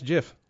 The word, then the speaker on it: GIF: It's pronounced GIF, like the peanut butter, but like you might as well be calling Pete the N-word over here.